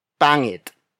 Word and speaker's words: pangit: For example, pangit, pangit, pangit. Pangit is spelled as P-A-N-G-I-T.